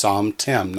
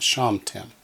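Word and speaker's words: samtem: It's samtim, not shamtim.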